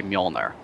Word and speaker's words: Mjolnir: And for those who, it's actually Mjolnir, but the J is pronounced like a Y, so you say Mjolnir.